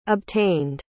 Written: obtained

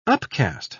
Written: upcast

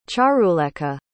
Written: charulekha

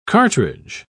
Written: cartridge

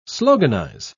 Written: sloganize

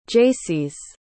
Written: jaycees

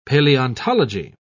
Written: paleontology

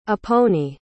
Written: aponi

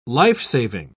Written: lifesaving

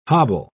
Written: hobble

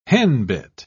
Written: henbit

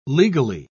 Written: legally

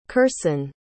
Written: kersen

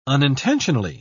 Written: unintentionally

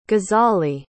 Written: gazali

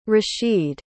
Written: rasheed

